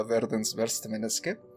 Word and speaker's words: Verdens verste menneske: que según la asesoría de Ali aquí presente se pronuncia Verdens Verstemeneske en noruego.